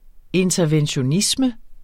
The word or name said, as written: interventionisme